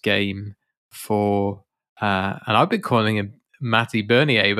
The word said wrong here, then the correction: Beniers: but I've realized that I'm pronouncing that entirely incorrectly, and it's Beniers.